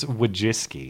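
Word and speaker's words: Wojcicki: What is her last name? Wachowski? – It's Wajiski.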